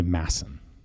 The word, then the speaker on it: Madsen: So it's actually Madsen. You don't pronounce the D.